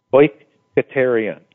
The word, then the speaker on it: oiketerion: Well, this is how, at least phonetically, how you would pronounce it: O Y K A Y T A Y R E E O N. Oiketerion.